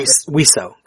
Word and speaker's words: Wiseau: It'd be Wiseau, because it's a respelling of the French word for bird.